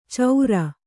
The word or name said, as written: ಚೌರ